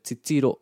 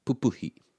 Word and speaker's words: titiro: Just with that word, titiro, whenever we have this double up in the two first syllables, papaki, titiro, pupuhi. So you got the two first syllables in a word of three syllables. The stress is on the middle one.